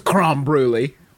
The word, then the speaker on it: crème brûlée: Weekly segment on funny ways to mispronounce creme brulee. – Mm-hmm. – Uh-huh. Spoiler alert: it's creme brulee.